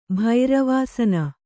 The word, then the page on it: ಭೈರವಾಸನ: bhairavāsana